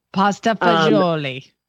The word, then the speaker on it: pasta e fagioli: Did I say that right? – Pasta e fagioli. That's right. You did. – Pasta fagioli. – Because no matter what Dean Martin tells you, there is no Zool in pasta fagioli.